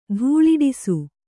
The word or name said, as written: ತೇಲಾಡಿಸು